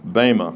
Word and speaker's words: bema: and it's the word bema. That's how it's pronounced, bema.